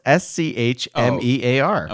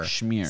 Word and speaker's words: Schmear: No, it's S C H M E A R. – Oh, I was thinking of 7-letter word. – I was thinking of smear. – Schmear. – Schmear. – Say it like a Jew. Schmear. Schmear. It's got the CH.